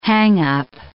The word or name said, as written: hang-up